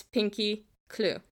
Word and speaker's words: Pinkie Cleugh: I'm pretty sure it's Pinkie Cleugh. And no, it's not spelled like I have a clue. It's yeah. Cleugh is spelled C-L-E-U-G-H.